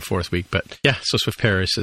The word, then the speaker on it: Swift Paris: So we heard from our friend Marin Tordov, you know, sometimes host of this show, and he mentioned, I mentioned him appearing on Swift Paris, or Swift Paris, as it should be pronounced, but he was telling me that it's actually a meetup.